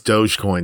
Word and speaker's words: Dogecoin: You do know it's Dogecoin, not Dogecoin.